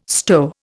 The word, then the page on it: stow: Indian